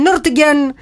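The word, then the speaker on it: Nürtingen: Καλησπέρα στον Νίκο, ο οποίος μας στέλνει από τον Νούρτιγκεν. – Από τον Νούρτιγκεν. – Το είπα έτσι, ούτε νου ούτε νι. – Ναι, μπράβο, να είσαι μέσα. – Νούρτιγκεν, το είπαμε προφορά.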